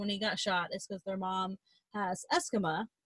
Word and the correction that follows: eczema: No, that's not how you say it. How do you say that? The, like, flaky skin, when you're, like, your skin is super... – Eczema. – Eczema. That's the one.